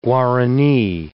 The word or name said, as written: guarani